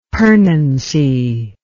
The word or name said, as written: pernancy